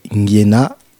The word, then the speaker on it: Ngiena: Et le titre que je préconise, c'est Ngiena. Donc ça s'écrit N-G-I-E-N-A.